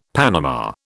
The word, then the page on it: panama: British English